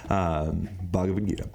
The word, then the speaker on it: Bhagavad Gita: One of the big takeaways from the Bhagavad Mother Flippin' Bhagita, I still said it wrong, Bhagavad Gita,